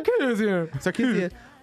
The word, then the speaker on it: Sarkisian: Her real name is Sherilyn Sarkisian. – Sarkisian, is that how you say it? – Sarkisian.